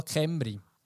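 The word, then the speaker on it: Cymru: Man schreibt es mit C, Zimmeru schreibt man, aber man sagt offenbar Kemri. Okay, sie werden offenbar Kemri sein.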